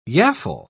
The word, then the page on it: yaffle: British English